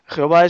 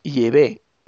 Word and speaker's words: Yahvé: Parece ser que el nombre personal de Dios es Yahvé. Parece que esa es la auténtica pronunciación, pero no Jehová, sino Yahvé.